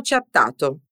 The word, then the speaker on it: chattato: Abbiamo chattato. Abbiamo chattato. Quando diventa un verbo italiano, chattare raddoppia la lettera T, dunque attenzione alla sua pronuncia.